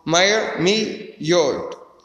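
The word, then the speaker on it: Mermillod: The first one is Cardinal Mary Milord. And on a day like today, he was preaching about Christ the King. Oh, his name is pronounced, I got the pronunciation here. Cardinal Mayer Milord.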